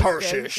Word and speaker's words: Tarshish: Yeah, that's how you say it. – You just got to say it confidently. – It's Tarshish. Good.